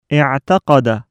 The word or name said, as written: اِعْتَقَدَ